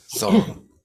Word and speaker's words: Xuanzang: A Yogacara scholar whose works would be influential in China had recently been the abbot of Nalanda and his disciple Xuanzang's teacher. Srila Bhadra was the leading scholar at the monastery, at least according to Xuanzang's account. Xuanzang. – Yeah, keep the second syllable simple. – Yeah. Zong. Xuanzang's.